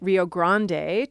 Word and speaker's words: Rio Grande: Rio means river, and you and I are both pronouncing it Rio Grande to try to capture its Spanish origins. You will hear people say the Rio Grande, which is a sort of Englishified version of that.